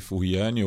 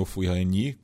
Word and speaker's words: Furiani: E ela é, me lembrou né, que hoje completam-se 30 anos é, da catástrofe de Furiani ou Furiani, é, enfim, de, tem, tem, as duas pronúncias que eu acabei ouvindo na imprensa francesa, né?